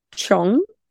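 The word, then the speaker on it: Cheong: My next one is called Every Night at Midnight, and it is by Peter, I was going to say Cheng. How did you pronounce his last name? – Look, I actually watched 2 YouTube clips earlier today. – Right. – And they said Chong, but Chong. – You... – Oh, that makes more sense, because there is definitely an ong sound. It's C-H-E-O-N-G.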